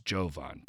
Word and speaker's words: Jovan: I believe it's Jovan, P.S., with a hard J, with a hard J.